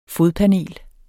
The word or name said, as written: fodpanel